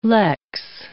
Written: lex

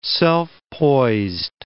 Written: self-poised

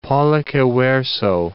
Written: pollice verso

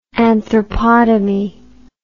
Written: anthropotomy

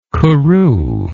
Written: couru

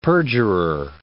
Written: perjurer

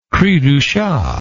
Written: cri du chat

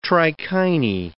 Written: trichinae

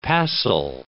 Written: passel

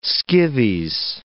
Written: Skivvies

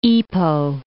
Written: Ipoh

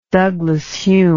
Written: Douglas-Home

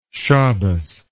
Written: Shabbas

